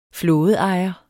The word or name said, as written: flådeejer